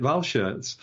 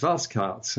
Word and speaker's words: Walschaerts: Because we've always made it German and called it Walschaerts. But Sven is telling us it's Walskards, and I can believe that because Belgian pronunciation is very different from German pronunciation.